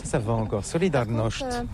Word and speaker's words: Solidarność: Alors solidarité, en français on dit solidarnosc, mais ici à Gdansk on ne prononce pas solidarnosc. – Solidarność.